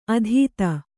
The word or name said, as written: ಅತೀತ